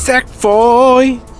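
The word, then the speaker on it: Sackboy: 색보이. 자, 발음 잘하셔야 됩니다. 네, 색보이입니다. 색보이. 네, 색보이에요 색보이. 색보이가 어. 아니고요. 색보이.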